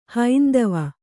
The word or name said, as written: ಹೈಂದವ